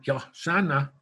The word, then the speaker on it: Hosanna: Hosanna, Yahsana is how they would pronounce it, is Yahweh save us.